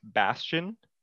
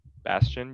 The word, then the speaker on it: Bastion: I think the bad guy is it pronounced Bastion? – Yeah. – Bastion, yeah.